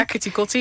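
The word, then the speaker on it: Keti Koti: Ketikoti, hoop ik al dat ik goed uitspreek. – Ja, Ketikoti, ja.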